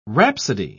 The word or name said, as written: rhapsody